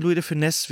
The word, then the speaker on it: Louis de Funès: Nein, Louis de Funès wird er ausgesprochen.